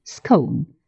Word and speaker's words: scone: Чай, пирожки, булочки, «scone» произносится с коротким «о».